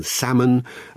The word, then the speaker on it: salmon: That's why we have a silent L in salmon.